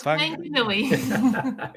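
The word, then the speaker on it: Whanganui: No, it's Wanganui. – Isn't the WH pronounced like an F? – Okay. No, that one is Wanganui.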